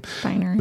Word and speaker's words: Spiner: Spiner.